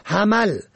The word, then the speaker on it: حمل: حمل به فتح ه و میم ماه اول سال شمسی است که شماری آن را ماه حمل می خوانند که سخت نادرست است.